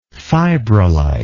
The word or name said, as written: fibrolite